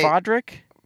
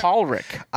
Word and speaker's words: Podrick: I thought it was Paulrick. – I thought it was Paulrick too, but the IMDb said Podrick. And Podrick. – Maybe they say it Paulrick. – Podrick.